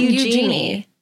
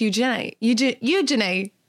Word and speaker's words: Eugenie: It's not Eugenie. – It's Eugenie. – Yeah. – You got to get a little Cockney accent going there. – You have to say it like, use your knee.